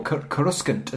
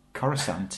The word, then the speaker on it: Coruscant: Or Coruscant, as my friend used to pronounce it, and we had a big debate. We had a big pre-internet debate over it. And I was like, I'm sure it's Coruscant. He's like, no, it's Coruscant.